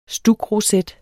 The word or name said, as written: stukroset